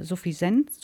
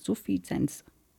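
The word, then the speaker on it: Suffizienz: Viele nennen das äh, Suffisenz, Suffisenz mit Z. Also es wird anders ausgesprochen.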